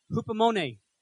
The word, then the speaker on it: hupomone: This is the Greek word, hupomone. That's the word. I'm pretty sure I'm saying it right. Hupomone. H-U-P-O-M-O-N-E with an accent on the E.